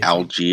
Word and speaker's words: Allgaier: I cannot remember how to say it. Algier, running back, I believe. Tyler Algier. – Algier, yeah.